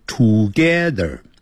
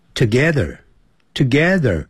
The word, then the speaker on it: together: 不要念成 together 要念 together 那个 T-O-G-E-T-H-E-R有个 R 结尾所以在美式的发音呢要卷舌的 together I live together 我生活在一起 live 就是生活 together 就是在一起跟谁在一起呢 with 就是跟着随着我爸妈住在一起所以这个 live 可以代表生活或者是住我跟爸妈同住，就这么说 I live together with my parents.